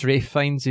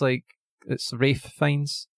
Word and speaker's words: Ralph Fiennes: No, it's Ralph Fiennes. It's Welsh. – Is it? – Yeah. – Yep. – Yeah. So how do you pronounce it? Ralph? – R-A-F-E. It's Ralph Fiennes.